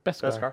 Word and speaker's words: Beskar: Beskar.